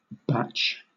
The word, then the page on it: bach: British English